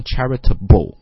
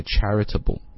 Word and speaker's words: charitable: 是这个 b sound 发重了。啊，所以读的时候不要读成 charitable 啊，不，这个音太重了。这个音其实非常轻的激动激了一下啊，就不 Okay, so we're going to pronounce it like a charitable, 啊，很轻的 b 打上去就行了。